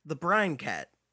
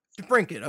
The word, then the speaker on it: DeBrincat: Is that how you pronounce that name? I always thought it was the Brine Cat. – Yeah. – DeBrincat, okay.